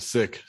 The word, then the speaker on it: Sikh: So we pronounce it Sikh.